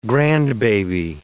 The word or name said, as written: grandbaby